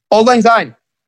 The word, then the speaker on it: Auld Lang Syne: When my drummer, Isaac, introduced me to old song line, is how you say it, right? – Auld Lang Syne. – Auld Lang Syne? Wow. – Yes. – I botched that hard.